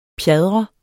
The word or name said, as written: pjadre